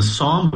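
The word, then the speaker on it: Sahm: Help us understand, you write about the sham rule. – Yeah, so the Psalm rule, I think, is how they say it.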